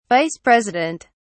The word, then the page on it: Vice President: British English